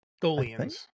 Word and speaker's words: Tholians: Tholians.